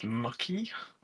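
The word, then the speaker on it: Muçi: I mean, Ernest Mucky, that's how to say it, plays for Legia Warsaw, in Warsaw.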